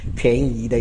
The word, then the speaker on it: 便宜: 哦，我们一听到便宜都很高兴，但是这个不是贵和便宜的意思，这里呢其实应该念作便宜哦，